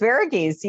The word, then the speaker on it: Varughese: It's Varughese.